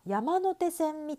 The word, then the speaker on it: 山手線: あんまり気にしていないと思うんですけど、あ、これは山手線みたいですね。